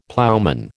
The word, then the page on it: ploughman: American English